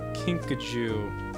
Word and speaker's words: Kinkajou: All right, Kinkajou. Kinkajou, that's how you say it.